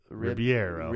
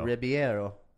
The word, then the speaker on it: Ribeiro: Is it Ribeiro? I can never pronounce it. – It's got the I on there, so it would be Ribeiro. – Ribeiro.